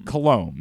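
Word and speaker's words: Coulombe: Colombe. That's how you pronounce it.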